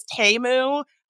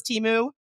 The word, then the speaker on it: Temu: Actually, apparently it's Temu. I've been saying it wrong this whole time, but I thought it was Temu.